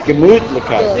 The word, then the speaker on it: Gemütlichkeit: Gemütlichkeit. – Gemütlichkeit? – Gemütlichkeit.